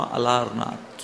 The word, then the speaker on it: अलारनाथ: अलवरनाथ का फिर प्रोनाउंसिएशन हुआ अलारनाथ।